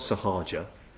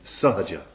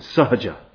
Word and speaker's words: Sahaja: So they sang of Sahaja, very often made, not Sahaja, it's Sahaja, that's the proper pronunciation, Sahaja.